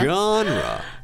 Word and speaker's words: genre: It's not easy, guys. – 它的拼写是 G-E-N-R-E.、 Mm-hmm. – 但是它的发音有点奇怪的原因是它最先是一个法语词。 – Right, right. So if you say genre,、that's fine, but it kind of sounds like a name.